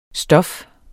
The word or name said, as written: stof